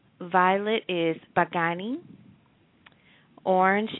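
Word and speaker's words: bagani (बैंगनी): The third color is going to be violet, and violet is pronounced bagani. Say it again, bagani, and that is spelled B as in boy, A-I-N as in Nathan, G as in ground, A-N-I. So B-A-I-N-G-A-N-I, and it's bagani, and that's violet.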